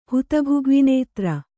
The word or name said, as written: ಹುತಭುಗ್ವಿನೇತ್ರ